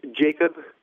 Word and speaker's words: Jacob: It's Captain Jacob Estrada, J-A-C-O-B-E-S-T-R-A-D-A.